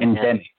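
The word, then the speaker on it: Ndemic: It's spelled Nedemic Creations. So I think it's Demic Creations, maybe a silent Endemic. Endemic. – Endemic. – So it's sort of playing on pandemic.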